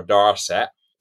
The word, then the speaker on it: Dorset: Well, we were in Dorset, or Darset, to give it the correct pronunciation.